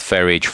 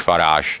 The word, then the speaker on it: Farage: Třeba ten nechválně proslulý anglický politik Nigel Farage, on říká, mě jedno, můžete mi říkat Farage, Farage eh, a, a tak dále.